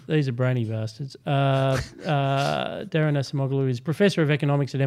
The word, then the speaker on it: Acemoglu: And I believe that you are nailing the pronunciation of his last name, Acemoglu.